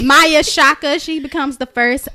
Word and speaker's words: Maia: Maya, I believe that's how you say it, M-A-I-A. Maya, yep.